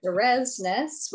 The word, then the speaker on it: Derosnec: Derosnec, which is not how it's spelled, but it's censored backwards.